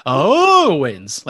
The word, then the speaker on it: Owens: And she brought up the Kevin Owens, how you had to put emphasis on the O. Okay. So, yeah. – You can put emphasis on the O, but you don't have to do the O.